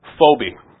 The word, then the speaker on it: Forbe: Phobe. It's actually spelled F-O-R-B-E, but it's the R is silent. And it's near Jean Rabel, which is J-E-A-N, and it's, I think, R-A-B-E-L.